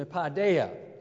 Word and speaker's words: paideia: Excuse me, paideia. Phonetically, it would be spelled P-A-I-D-E P-A-I-D-E-I-A. P-A-I-D-E-I-A.